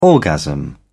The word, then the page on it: orgasm: British English